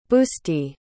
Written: busti

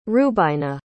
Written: rubaina

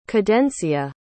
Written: cadencia

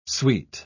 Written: suite

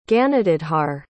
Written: ganadadhar

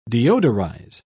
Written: deodorize

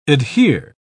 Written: adhere

